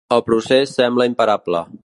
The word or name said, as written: sembla